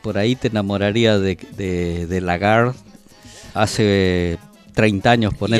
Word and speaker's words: Lagarde: Christine Lagarde. – Lagarde. – Porque la E, viste, se pronuncia Lagarde. Así.